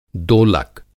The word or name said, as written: दोलक